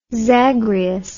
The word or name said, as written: zagreus